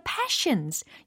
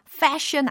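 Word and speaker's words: passion: 자, passion이라고 했어요. fashion 아닙니다. 이거 신기한 게꼭 fashion이라고 발음해야 할 때는 passion이라고 하고 passion이라고 해야 되는데 fashion이라고 하시는 분들이 꼭 계시더라고요. 주의해 주셨으면 좋겠어요.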